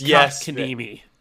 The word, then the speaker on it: Kotkaniemi: It's Kotkaniemi. Jesper Kotkaniemi. – Where is he from? – He played for the Montreal Canadiens. – No, what country? – I have no idea. – Whatever. – Jesper Kotkaniemi.